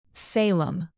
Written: salem

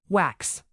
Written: wax

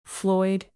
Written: floyd

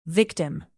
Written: victim

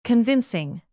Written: convincing

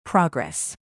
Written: progress

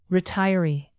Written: retiree

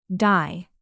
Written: dye

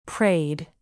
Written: preyed

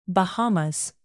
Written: Bahamas

